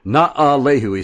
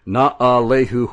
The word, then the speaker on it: Na'alehu: And he is from Na'alehu. Na'alehu. He spelt it phonetically for me. Na'alehu, Hawaii.